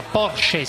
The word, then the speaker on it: Porsche: Siamo qui a Verona, un aggiornamento dalla mitica sede della Porsche, si pronuncia così, Parenzo? – Sì, lo so.